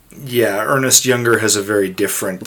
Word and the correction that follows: Ernst: It's Ernst, right? – Ernst.